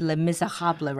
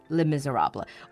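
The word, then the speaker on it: Les Misérables: Well, I'm sure lots of people already know, but the musical today is Les Miserables Mm, Les Uh, the French pronounce it le misérable, right? Like with the R sound. But English speakers would just say le misérable or Les Mis.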